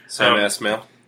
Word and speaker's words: Sam Esmail: The creator, Sam Esmail, name I can't pronounce. – Sam Esmail.